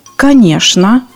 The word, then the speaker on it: конечно: Однако в некоторых словах «чин» произносится как «шин». Вот самые частотные слова, где произносится «шин». «Конечно», «скучно», «нарочно», «яичница», «пустяшный», «скворечник». Произношение этих слов нужно запомнить.